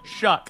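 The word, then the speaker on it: Shough: I think Tyler Shuff, whatever his name is, Shaw, whatever you pronounce shuck. – It. – Shuck, shucking oysters. Shuck.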